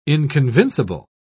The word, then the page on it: inconvincible: British English